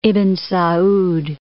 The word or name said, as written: Ibn Sa'ad